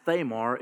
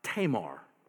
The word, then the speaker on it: Tamar: I've never heard her name pronounced Thamar. It's usually pronounced Tamar.